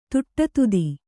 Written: ತುಟ್ಟತುದಿ

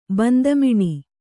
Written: ಬಂದಮಿಣಿ

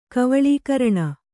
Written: ಕವಳೀಕರಣ

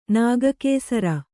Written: ನಾಗಕೇಸರ

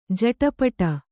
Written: ಜಟಪಟ

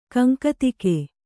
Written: ಗಂಗೋದಕ

